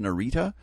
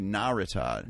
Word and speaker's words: Narita: This is a total aside, but I was in the airport in Tokyo, which I've always pronounced Narita. I had a change over there, but over the intercom, I heard them say Narita. – Narita. – Narita. So I believe that perhaps Japanese, when pronounced correctly, accents on the first syllable.